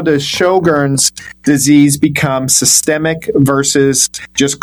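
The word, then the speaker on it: Sjögren's: Again, that we're talking about Sjogren's, and that is spelled S-J-O-G-R-E-N. Apostrophe S disease. That's for our listeners. So it's Sjogren's.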